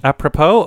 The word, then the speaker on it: apropos: Then that's my songs are apropos. – Apropos? – Is that how you say it? Apropos? – Mm-hmm. – Apropos or apropro? – Apropos. Apropos statements.